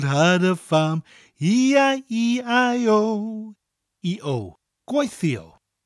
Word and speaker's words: gweithio: The first part of gwaithio is pronounced exactly the same as gwaith, but the spelling is slightly different. Gwaith is G-W-A-I-T-H, and gwaithio is G-W-E-I-T-H-I-O. So we have the gwaith sound followed by E-O, gwaithio.